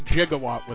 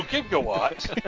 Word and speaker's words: gigawatt: And it's gigawatt with a soft G. – So, gigawatt.